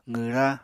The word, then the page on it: qwra: apatani